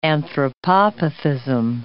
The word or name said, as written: anthropopathism